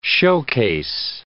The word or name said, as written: showcase